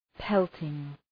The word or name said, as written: pelting